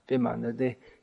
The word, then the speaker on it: Biman: Biman. The airline of Bangladesh is called Bangladesh Biman. Biman means B the Bengali pronunciation of V.